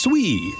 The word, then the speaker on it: SWEI: He and his team developed an index, the Seattle Weather Excitement Index, SWEI, pronounced SWEE.